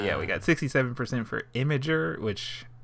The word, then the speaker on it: Imgur: Imgur. And yeah, the results are in. They've been tallied, and the internet has spoken. It's Imgur, not Imgur.